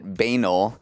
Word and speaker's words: banal: I said the word B-A-N-A-L out loud and pronounced it banal. As soon as I said it, I knew that was totally wrong, and I just knew I was going to get so many texts about people making fun of me for pronouncing it wrong. If you want to know how to correctly pronounce it, which I knew but didn't say it, it's banal.